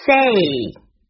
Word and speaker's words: say: 也要注意使用复数形式 CITY City DRESS Dress FAST Fast MISS Miss OFFICE Office PAST Past SAY Say 这个词很多人会把它读成 CAY 发音是 A Say SEE